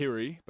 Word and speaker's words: Hire: No, so it's Hiri, but my yeah.